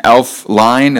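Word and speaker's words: Elflein: By the way, for the Vikings, their center is out today, Pat, I can't pronounce his last name, Elf Line, is that correct? – Yeah, exactly. Yep, you got it.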